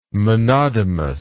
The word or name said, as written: monodomous